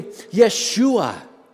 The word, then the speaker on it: Yeshua: If you were worshiping in a Hebraic setting, you'd be worshiping Yeshua, the Hebraic pronunciation of Jesus,